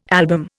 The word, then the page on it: album: Indian